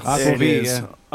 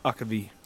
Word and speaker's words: Aquavit: And that can't be proper pronunciation, is it? – Yeah. – Yes. – Aquavit. – Aquavit.